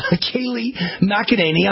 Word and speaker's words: Kayleigh McEnany: Kayleigh McEnany. I'm pronouncing it right, I hope. Yes? – That's right. Yes, you're pronouncing it right.